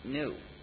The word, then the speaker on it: GNU: The reason this word is used for so much humor is, according to the dictionary, it's pronounced new.